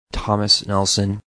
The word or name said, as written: Tom Nelson